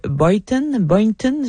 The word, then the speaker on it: Boynton: Boynton. Est-ce que vous savez comment prononcer ça? C'est B-O-Y-N-T-O-N. Boynton.